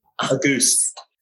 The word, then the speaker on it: Auguste: Auguste. – Auguste. Auguste. Man. – You have it correct. You're just putting that E at the end of it.